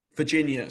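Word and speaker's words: Virgínia: Virginia, sorry. It's a hard G and it's a hard J in Portuguese as well.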